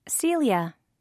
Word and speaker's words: Celia: G-E-O-R-G-E. – 2. – What's your name? – Celia. – That's a pretty name. How do you spell it? – C-E-L-I-A.